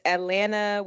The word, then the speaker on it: Atlanta: Typically, it's Atlanta with no second T or the A or ATL.